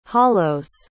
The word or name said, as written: hollows